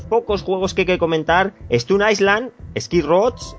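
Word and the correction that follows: SkyRoads: no sé muy bien cómo se pronunciaría este, Castañer, sería... – Sky Roads.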